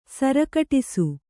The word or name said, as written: ಸರಕಟಿಸು